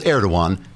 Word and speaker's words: Erdoğan: Personally, I'd have told Erdoğan to et stuffed. The G is silent.